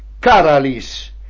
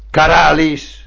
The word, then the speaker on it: Caralis: e sulla fiancata spiccava il nome Caralis, con l'accento sulla prima A, l'antico nome di Cagliari, fondata dai Fenici, e subito, come tanti altri continentali, pronunciammo in modo errato Caralis.